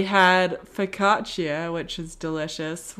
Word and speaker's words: focaccia: Just listen to say focaccia.